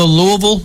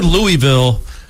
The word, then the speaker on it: Louisville: And he basically just said, try to get as many L's in there as possible. – Yeah, because I was going to say... – Because I've said Louisville forever.